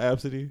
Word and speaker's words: Abcde: Do you know about Absidy? Mm-mm. Oh, it's spelled A-B-C-D-E.